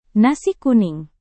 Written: nasi kuning

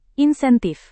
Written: insentif

